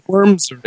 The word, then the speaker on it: Worms: It's happy Wormrin Day to you. Happy St. Wormrin Day to you, too. It is a holiday today. And I think it's Worms is the pronunciation.